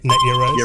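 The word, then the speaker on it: Net Yaroze: I can never pronounce this right. Is it the NetYaroos or Yarols? – Yep, correct. Yep. NetYaroos. – Yaroos, there we go.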